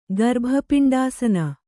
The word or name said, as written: ಗರ್ಭಪಿಂಡಾಸನ